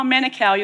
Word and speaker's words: Manukau: So I got quite a shock when I came to Auckland and caught a bus to Manukau, and the lady went, "Where?" I went, "Oh, I don't know how to say it." And then they said, "Oh, Manukau, you're going to have to really learn how to say that word."